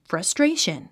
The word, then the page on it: frustration: frʌstréiʃən; フラストレイション